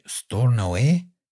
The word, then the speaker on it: Stornoway: We have listeners in Aberdeen, and uh, this place called I think it's called uh, I think I pronounced it right Stornoway.